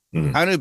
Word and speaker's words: Hanwoo: anytime you see WOO, the W's silent. – Right. W. – Hanwoo. Hanu. Hanu beef.